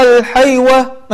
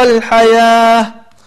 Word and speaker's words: الحياة: وكلمة الحياة لو نلاحظها أنها مكتوبة بالواو، ولكن هذا هو الرسم العثماني، بعض الكلمات تكتب بالواو ولكنها تنطق بالألف مثل الزكاة أو الصلاة أو الحياة. طيب فتلاحظ أنك لا تقول وآثر الحيوة مثلا، ولكن تقول وآثر الحياة.